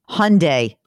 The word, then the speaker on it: Hyundai: Hyundai rhymes with Sunday.